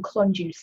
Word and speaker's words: Klonjuze: It's spelt, she tells me, K L O N J U Z E, Klonjuze.